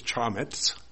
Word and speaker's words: chametz: Now, the word leaven in the Hebrew is, I don't know, probably can't pronounce it right, but to me it's chametz. Chametz? And it's spelled C-H-A-M-E-T-Z.